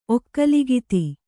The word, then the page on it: ಒಕ್ಕಲುಗಿತ್ತಿ: okkalugitti